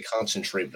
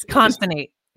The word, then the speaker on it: Concinnate: So when you reach out to your customers, first tell me how to pronounce the name of your business, because I was going to say concentrate, but I know it's not what it means. But it's Constantate.